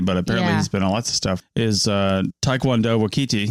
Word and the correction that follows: Taika Waititi: Taika Waititi. It's not that hard.